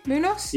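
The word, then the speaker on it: Muñoz: Munoz? – Yeah, Ruben Munoz.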